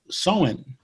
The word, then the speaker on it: Samhain: but it began as something called Samhain, S-A-M-H-A-I-N. I spelled it out because it's spelled one way, but pronounced a different way. Samhain is the way it's supposed to be said, and it was a Celtic holiday.